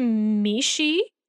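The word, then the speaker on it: meishi (名刺): So it's called a meishi, M-E-I-S-H-I, in both Japan and China, pronounced a little bit differently, but meishi.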